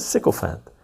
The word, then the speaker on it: sycophant: Toady, leech, sponge, parasite means and un- means usually and usually obsequious flatterer or self-seeker sycophant sycophant oh is that how you pronounce it sycophant sycophant sycophant